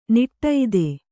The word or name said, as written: ನಾಡಿದು